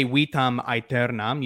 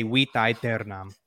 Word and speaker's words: vitam aeternam: I know the really trippy thing with classical Latin is that if a word ends with an M and the next word starts with a vowel, you don't pronounce the M. So you don't say vitam aeternam, you say vita aeternam.